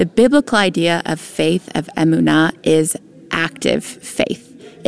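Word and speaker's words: emunah: Emuna, or emuna, more properly pronounced in Hebrew.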